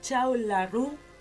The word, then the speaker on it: Cao Laru: Y como lo habíamos anticipado, tenemos aquí en el estudio a la banda Chau La Rue. – Exacto. – Perfecto. Ahí lo pronuncio bien.